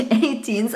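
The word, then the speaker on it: A-Teens: Weil das hieß nämlich 18s ausgesprochen tatsächlich.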